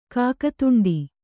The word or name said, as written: ಕಾಕದೊಂಡೆ